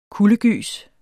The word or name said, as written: kuldegys